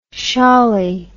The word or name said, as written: shawlie